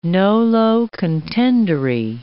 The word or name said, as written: nolo contendere